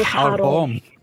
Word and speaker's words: haram: Well, I'd like to maybe correct, without stepping on anyone's toes there, the correct pronunciation of the word haram. It's actually haram.